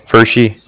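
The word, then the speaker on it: Pferschy: Oh, yeah, it's Fershey.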